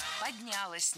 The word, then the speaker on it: поднялось: Как это предприятие поднялось? Как там развилось? Когда его двигали там с одного предприятия на комплекс предприятий? – Поднялось неверно. Правильно, поднялось. Ударяем окончание. Развилось тоже не лучший вариант.